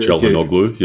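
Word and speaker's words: Çalhanoğlu: And please, people, forgive me for my pronunciation because it's not always easy, but Hakan Calhanoglu, he's right. – Their talisman, hey? – I said Calhanoglu, yep, that's all right.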